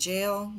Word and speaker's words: gaol: They visited the historic Maitland Jail, which is spelled G-A-O-L, the old-fashioned way, the old-timey way.